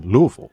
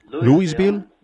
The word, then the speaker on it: Louisville: Ahora, allá algunos lo pronuncian Louisville, Louisville y Louisville. Y no sé cómo sea aquí. Creo que nosotros, la mejor manera como siempre lo he pronunciado era Louisville. Parece que L-O-U-I-S sería Louisville. Pero allá en casa es Louisville, Louisville y Louisville. Así que solo escogemos el que queramos usar.